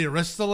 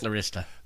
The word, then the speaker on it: Arista: No, I actually, this was the first one on the Arista label, or Artista, or however the hell you pronounce it. – Arista.